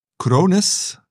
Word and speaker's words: Krones: Een, een Duitse familiebedrijf, Krones. Spreek je dat zo goed uit? Zeker.